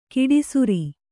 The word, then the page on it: ಕಡುಸಾರೆ: kaḍusāre; kannada